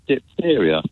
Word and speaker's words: diphtheria: She talked about diphtheria. Well, it's not spelled even diphtheria. It's spelled D-I-P-H-T-H as diphtheria.